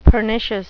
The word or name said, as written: pernicious